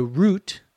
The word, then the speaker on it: route: The route, R-O-U-T-E, sometimes called the route, both pronunciations are possible, is the path or the direction that the bus goes.